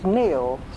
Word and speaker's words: Neale: Before I married, my surname was Neale, spelt N-E-A-L-E, rather like my Vicary is spelt V-I-C-A-R-Y.